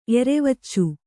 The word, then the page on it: ಎರೆವಚ್ಚು: kannada